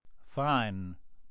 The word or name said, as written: fine